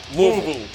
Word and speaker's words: Louisville: Louisville, Kentucky. Louisville. You got to say it like you've got a mouthful of marbles. – Louisville.